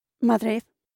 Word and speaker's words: Madrid: The capital of Spain, Madrid, in Spanish is simply pronounced as... – Madrid. – Madrid.